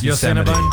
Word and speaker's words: Yosemite: Yes, Yosemite.